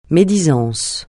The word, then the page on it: médisance: francais